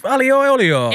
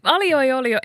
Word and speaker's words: aglio e olio: Aglio, meaning garlic, in Italian, e olio. Aglio e olio.